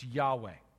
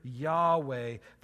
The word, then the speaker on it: Yahweh: It's Yahweh, right? Sometimes pronounced Jehovah. It's four Hebrew letters, and so we have to fill in our own vowels, and so you pronounce it different ways.